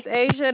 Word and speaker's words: Asia: My name is Asia. It's spelled A-S-I-A. – A-S-I-A?